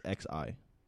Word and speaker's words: Xi: Is that how do you, how would you pronounce XI? – Xi. – So Xi Jinping.